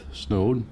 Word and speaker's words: Snode: And I'd like to introduce Kevin Cabe and Keith Snode. Did I get that right? S-N-O-D-E, Snode.